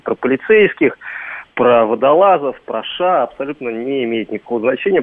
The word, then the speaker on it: США: Причем, да, причем преподаватель так неразборчиво говорил, и у него все время не США, а США получалось, агрессия США. – Он просто использовал старое произношение, тогда, я напомню, не США говорили, а именно США. Тогда вот такая была норма принята, и он, конечно, человек старой закалки, это чувствуется.